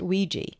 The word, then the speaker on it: Ouija: Take the Ouija board, pronounced Ouija.